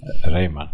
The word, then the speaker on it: Rayman: Rayman.